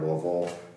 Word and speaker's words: Louisville: And Zach, good pronunciation on the word Louisville. For our listeners, Zach was kind of practicing beforehand. – They don't teach us that up in Ohio. – I think you nailed it.